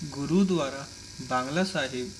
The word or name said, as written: Gurdwara Bangla Sahib